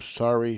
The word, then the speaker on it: Sari: We'll start off with a New York artist named Sari Shore. Sari is spelled S-A-R-I, by the way, not S-O-R-R-Y, like it sounds like when I'm saying it.